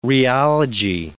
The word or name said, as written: rheology